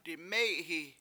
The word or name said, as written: diMEy7hi7